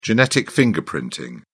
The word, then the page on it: genetic fingerprinting: British English